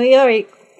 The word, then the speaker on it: Iori: Kusano Iori they then Pronouns, Clarion West Class of 2017.